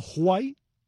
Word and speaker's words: Juai: El poema de Gastón Baquero se titula, el título por sí mismo es delicioso, Joseito Huay toca su violín en el Versalles de Matanzas. Ese Huay, señoras y señores, se escribe no White, como debe escribirse, sino J-U-A-I.